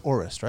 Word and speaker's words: Orest: And it's pronounced Orest, right? – Orest, yeah.